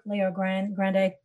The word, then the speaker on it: Leo Grande: But, you know, seeing these women owning and exploring their sexuality, such as Emma Thompson in Good Luck to You, Leo Grande. I think I said that right. Leo Grande. – Yeah. – I think of Ariana Grande.